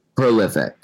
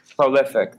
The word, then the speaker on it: prolific: He's profilic. Is that how you say it? – Prolific. – Prolific. That's it.